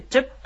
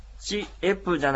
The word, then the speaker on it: チェップ: チエプじゃなくてチェップとこう一息に発音します、これ本当によく使う言葉ですので、みんなでそれぞれ発音してみようと思います、カタカナで書くとチェの次にちっちゃいプがきます。これはですね、アイヌ語の独特の発音ですので、チェプとかチェップじゃないんですね。チェップという発音です。